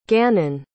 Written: gannon

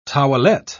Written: towelette

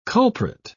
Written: culprit

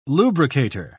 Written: lubricator

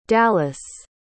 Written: dallas

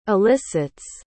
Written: elicits

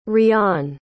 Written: Riaan

